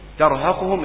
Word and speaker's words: ترهقهم: أبصارهم مش أب، أبصارهم، ترهقهم التاء مع الراء برضه عشان ما تفخمش، وقد كانوا يدعون إلى السجود وهم سالمون.